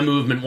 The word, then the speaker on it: MVMT: But I do trust my movement watch. Movement watches... pronounced very coolly, very few vowels used in that.